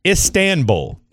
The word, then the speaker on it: Istanbul: So it's Instanbul. Is that the correct way to say it? – It's Istanbul. – Istanbul. – Thank you. – What am I saying? – You're saying it like it's Instagram bull.